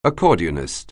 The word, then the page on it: accordionist: anglais